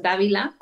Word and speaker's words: Dávila: Mi nombre se pronuncia Ginia, pero se escribe Genia con J y mi apellido es Dávila con D y con V.